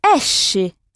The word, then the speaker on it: esce: Je vous ai dit tout à l'heure que « sc » suivi d'un « e » ou d'un « i » se prononce « esce ». – Esce, c'est ça.